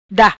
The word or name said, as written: Da